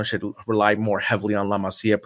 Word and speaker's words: La Masia: And this is going to go to my last point for our conversation today is La Masia, or as I've been seeing on TikTok, La Masia. That drives me crazy when people can't pronounce it correctly. La Masia just producing yet more gems.